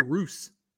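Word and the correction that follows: Reuss: Yes, Jerry Roos. – Jerry Roos. Jerry Royce.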